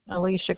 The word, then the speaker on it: Alicia: Alicia Kastner.